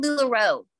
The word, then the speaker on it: LuLaRoe: Is it called LuLaRoe or LuLaRoe? – It's called LuLaRoe.